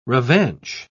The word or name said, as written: revanche